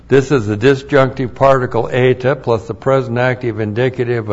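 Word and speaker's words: Eta (ἤ): The disjunctive particle eta. Looks like that's just one letter, a long E.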